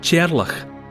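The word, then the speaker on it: Teàrlach: It's a mishearing of the Gaelic form of Charles, which is I apologise unreservedly for what's going to be my mispronunciation, but in Gaelic speakers' mouths, Charles is rendered into a name that sounds a bit like Cherlach.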